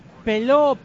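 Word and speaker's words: Pello: Peló, no sé, yo no pronunciaría la L. Yo diría Peló, a que sí.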